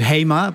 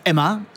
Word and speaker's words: HEMA: So, and for example, we the Dutch way of pronouncing it is HEMA, but our French colleagues, French customers call it Emma, and our Spanish customers call it Gemma.